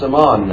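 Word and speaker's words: Amman: Ammon is the, well, it's pronounced Ammon now.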